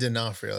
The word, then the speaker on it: D'Onofrio: Is Vincent D'Onofrio, is that how you say his name? – I say Vincent D'Onofrio, but D'Onofrio, that's how you say his name. That's the correct way.